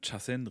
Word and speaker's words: Chasen: Nicole fragt, wie wichtig ist der Chasen und wie erkenne ich einen guten Chasen? – Das Erste, was mir schon mal auffällt, ist, dass du Chasen richtig ausgesprochen hast.